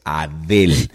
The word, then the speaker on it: Adele: Oye, hey, es Adele, y no eso ha dado la vuelta al mundo.